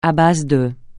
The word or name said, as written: à base de